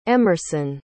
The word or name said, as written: emerson